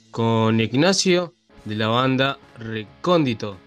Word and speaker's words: Recóndito: ¿Cómo se pronuncia así? ¿Recóndito? ¿Recóndito? Recóndito. El logo, viste, que tiene como una O atravesada. Eso sí. Eh, por eso pusimos el logo así, por el acento, yo.